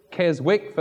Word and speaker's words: Keswick: This was at the Keswick Convention in North England, the original Keswick, that is. Keswick, for those of you who mispronounce English.